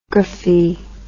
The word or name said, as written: graphy